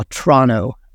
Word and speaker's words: Toronto: or Toronto as the locals say.